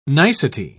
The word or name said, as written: nicety